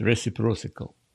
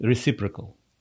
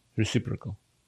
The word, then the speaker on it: reciprocal: Тарифы бывают обычные, а бывают reciprocal. Не так. Это слово звучит reciprocal. Reciprocal. Правильно произносить его.